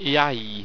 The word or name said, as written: iaei